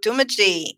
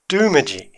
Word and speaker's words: Doomadgee: Somebody else who's launching this weekend, we've got Doomadgee in Queensland. – Now, what do you know about Doomadgee? – What I know is that everybody else pronounces it Doomadgee, but it's actually Doomadgee.